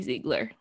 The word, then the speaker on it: Ziegler: I think it's Ziegler because I watched Dance Moms and it was always Maddie Ziegler. – Okay. Yeah. Maddie Ziegler is in it and she plays an autistic girl.